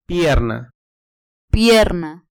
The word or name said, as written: pierna